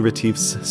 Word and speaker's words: Retief: Retief is spelled like relief, but trade out the L for a T.